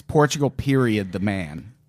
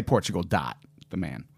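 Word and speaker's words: Portugal. The Man: And people don't realize you pronounce the period in that name. It's Portugal period the man. – Period. – Right. – Okay. – Or you can say Portugal dot the man.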